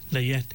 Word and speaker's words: layette: The layette is, it's spelled L-A-Y-E-T, and then there's a T-E at the end, which is like almost silent. You don't really pronounce it. It's just pronounced as layette.